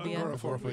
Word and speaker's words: agoraphobia: I would think it's like, is it pronounced agoraphobia? – The fear of agoraphobia. Yeah.